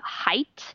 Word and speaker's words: Haidt: The Righteous Mind by Jonathan, I think you pronounce his last name, Haidt. It's H-I-H-A-I-D-T.